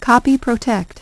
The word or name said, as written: copy-protect